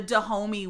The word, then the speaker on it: Dahomey: Dahomey? Is that how you say it? – The Dahomey women...